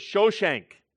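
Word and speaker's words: Shoshenq: In Egyptian hieroglyphics, his name is written and pronounced as Shoshank, which sounds a lot like Shishak.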